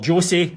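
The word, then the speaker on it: José: So, um, no, Jose or Jose rather, as I've been told, I should pronounce it.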